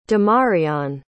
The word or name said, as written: damarion